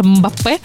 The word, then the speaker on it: Мбаппе: Це порода золотих рибок, названа на честь капітана збірної Франції Кіліана Мбаппе. Точніше, Мбаппе, наголос на останню голосну, на останній склад, поміщають у центр акваріума цю оранду Мбаппе, по краях якого розташовані маленькі футбольні ворота.